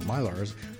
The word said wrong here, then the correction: Millar: I never could get his name right. It's It's just Miller. – Miller? – Yeah, Miller.